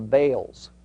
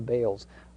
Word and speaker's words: Baal: I, I understand that the, the more technical pr pronunciation, probably more accurate, is Baal, okay? But uh, the more common use pronunciation is Baal, and that's even what the dictionary has as the pronunciation.